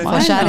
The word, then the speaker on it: follar: Entre la pronunciación este, con la L, que ya no me sale follar, follar, follar, no sé. – follar, es follar, se ha salido bien.